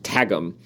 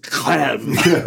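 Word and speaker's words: Qwghlm: I may have added or subtracted a consonant there, which according to the narrator of the audiobook is pronounced Tagum. I have always, in my brain, it was always Clam.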